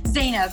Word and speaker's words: Zeinab: Yeah, Zainab.